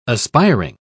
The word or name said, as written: aspiring